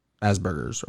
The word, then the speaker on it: Asperger's: Elon Musk admitted that he has Asperger's. – Yeah. – He said he's the first one to ever host SNL with Asperger's, or how you say it? – Asperger's.